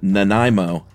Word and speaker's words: Nanaimo: Had the most hilarious, unique pronunciation of, well, I think it's the Nanaimo cookie is correct, right? – Yeah. It's what did you say? – Nanaimo.